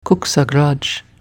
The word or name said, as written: Couchsachgrage